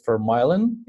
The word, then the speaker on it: Vermijlen: The name of the guest is Bart, and the last name is Vermijlen, if I say it right.